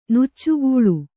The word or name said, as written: ನುಚ್ಚುಗೂಳು